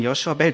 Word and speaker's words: Joshua Bell: Joshua Bell.